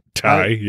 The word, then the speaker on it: Teige: Yeah, Tig, yeah. – Yeah, T-E-I-G-E.